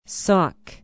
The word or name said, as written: sauk